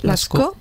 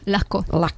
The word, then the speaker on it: Lascaux: Y entonces la cueva de Lascaux, ¿cómo se dice? – Lascaux. – Es que es francés, perdona. Francés ya nula. – Es como decir el asco. Lascaux.